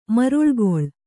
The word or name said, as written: ಮರುಳುಗೊಳ್